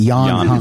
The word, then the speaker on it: Jan Hammer: I don't know if it's Jan Hammer. I think they pronounce it Jan Hammer.